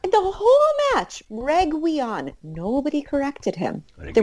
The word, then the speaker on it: Reguilón: And he was pronouncing Reguilón as Reguion. Now, I have a question. Okay? Because this doesn't seem to be, like, a particularly difficult name because it's phonetic. – Reguilón? – Well, okay, no, so I guess it's not phonetic. But there's no W anywhere.